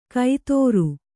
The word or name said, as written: ಕೈತೋರು